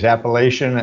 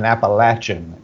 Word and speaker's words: Appalachian: There are two ways Appalachian and Appalachian, and it depends on what part of the country you're from, evidently.